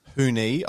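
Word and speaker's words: Huni: All right, so by the time this airs, the Paul Gallen and Justice Hooney, I'm told his name is supposed to be pronounced, not Justice Honey, which I think actually would be a marketing genius ploy.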